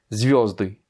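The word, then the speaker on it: zviozdy (звёзды): Zviozdy, qui est le pluriel du mot zvizda, qui signifie étoile. Eh bien, donc les étoiles, zviozdy, l'accent tonique est sur le yo.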